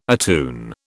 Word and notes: attune: British English

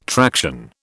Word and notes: traction: British English

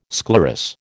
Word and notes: sclerous: American English

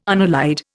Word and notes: unallied: Indian

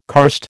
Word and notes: karst: American English

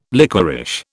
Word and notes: liquorish: British English

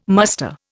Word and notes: muster: Indian

